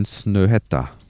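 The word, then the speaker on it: Snøhetta: In Snöhetta. oh, hast du gut ausgesprochen. Das hört sich richtig an.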